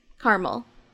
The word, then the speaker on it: caramel: Caramel. – Caramel. You just forget the second A. We just ignore it.